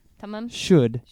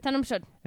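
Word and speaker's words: Tamám Shud: The Tamim Tamim Shud is how you pronounce it. – Tamim Shud.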